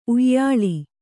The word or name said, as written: ಉಯಿಲು